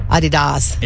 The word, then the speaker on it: Adidas: Adidas.